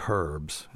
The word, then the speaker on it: herbs: And then they say herbs instead of, they pronounce the H, among other things.